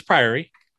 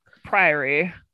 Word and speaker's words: Priory: So from Priory of the Orange Tree, I don't even think I'm saying that correctly. – No, you said, I think you are. It's Priory. – Priory.